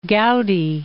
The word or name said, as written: Goudy